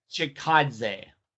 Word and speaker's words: Chikadze: Cub Swanson uh, going down in the first round, TKO body kick and punches Giga Chikadze. Did I say that right? Chikadze. – Giga Giga okay. – Chikadze.